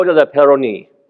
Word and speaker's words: Peyronie: If you all will indulge me, I'm going to work on my French pronunciation here. I did spend 10 years in Canada, after all, okay? This is the French surgeon, François Gigeau de la Peyronie. How'd I do? Pretty good.